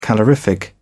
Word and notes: calorific: British English

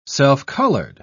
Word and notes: self-colored: British English

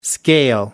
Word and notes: scale: American English

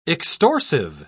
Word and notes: extorsive: British English

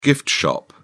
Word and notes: gift shop: British English

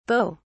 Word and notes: beauts: British English

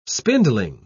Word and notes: spindling: British English

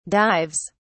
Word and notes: dives: British English

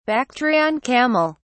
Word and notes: bactrian camel: British English

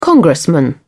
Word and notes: congressman: British English

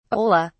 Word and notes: ola: British English

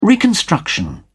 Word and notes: reconstruction: British English

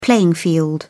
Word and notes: playing field: British English

